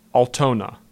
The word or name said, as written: Altona